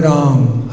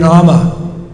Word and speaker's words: Rama: You're leaving too many important syllables out. You're chanting Hare Ram, Hare Ram, Ram Ram, Hare Hare. It's Hare Rama. Do you see it written Ram? No, sir. Yes? Hare Rama, Hare Rama, Rama Rama.